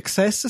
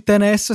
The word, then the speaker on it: XS: XS sembra extra small in italiano, in inglese a seconda di come lo pronunci, se lo pronunci XS sembra eccesso, se lo pronunci 10S sembra tennis e poi Max pro- ha lo stesso suono di Max plurale, i computer di Apple, i Max.